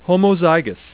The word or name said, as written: homozygous